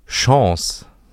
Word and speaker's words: Chance: Es gibt so viele Varianten, wie man dieses Wort aussprechen kann. Und zwar gibt es das immer mit diesem E hinten dran und nicht. Also es gibt einmal das Deutsche, so, das ist deine letzte Chance.